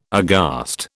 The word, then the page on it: aghast: British English